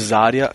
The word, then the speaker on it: Zarya: E o, o Zarya, Zarya é, em russo, pronunciar se Zarya, é amanhã, de manhã, né?